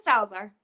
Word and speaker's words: Salazar: Mm-hmm, Salazar.